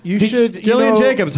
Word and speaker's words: Gillian Jacobs: Gillian Jacobs, hard G, soft J.